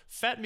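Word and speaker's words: Fête: was it pronounced Fet Music in Providence? Feedy Music? Which one was it? – Fet. – That makes more sense. So, Fet Music, Providence, Rhode Island, Beyond Wrestling, Americanrana.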